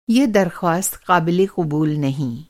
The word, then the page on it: acceptable: American English